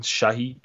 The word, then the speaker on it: Shahi: Uh, very cute lady, uh, Dani Reese, who was played by Sarah Sahi. I think I'm saying her name wrong, Shahi.